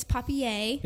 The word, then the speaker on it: Papier: The Painted Papier. It's Papier. – It's spelled. – It's French. It's French for paper.